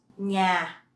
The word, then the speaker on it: nhà: The NH in Vietnamese, you pronounce nh, nh. So it's not like the N in English, okay? Nh, nh. Let's see how it looks in words. Nhà, nhà.